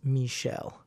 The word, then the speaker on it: Meshell: She is, her name is spelled M-E-S-H-E-L-L, so that's Michelle.